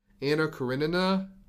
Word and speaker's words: Anna Karenina: Anna Karenina.